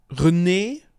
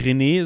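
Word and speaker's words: Renée: René, also nicht René, sondern René, würde ich es mal aussprechen. R-E-N-E -E mit Apostroph und noch ein E dahinter.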